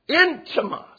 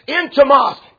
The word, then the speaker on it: intimos: In fact, the word translated dear in Luke 7, 2, where it said he was dear unto him, that word, the Greek word, see if I pronounce it right, see if it don't sound like one of our English words. Intimos. Y'all didn't know I knew Greek, did you? I don't. Amen. I just got things that tells me how to pronounce that stuff. But you know what? What does that sound like? Brother Jacob's already mentioned it. Intimos. Intimate is where we get ours.